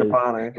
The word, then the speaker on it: Mangiapane: Andrew Mangiapane. I don't know how to pronounce it. – Mangiapane? – Yeah, there it is. Mangiapane. There we go.